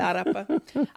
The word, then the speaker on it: arepa: I just tried the arepa, so I understand the arepa power now. Like, I'm in it. – I mean, good pronunciation. Yeah. – Right, the arepa.